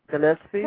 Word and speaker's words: Gillespie: Gillespie? – No, it's actually Kellen. Like Kellen. – Kellen. Yes. – Kellen. – Last name Gillespie, like Dizzy Gillespie.